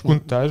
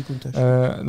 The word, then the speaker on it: Countach: Oui, alors 70, c'est la Countach, je ne sais pas exactement la prononciation. – Moi, je dirais Countach, voilà. – J'ai toujours dit Countach.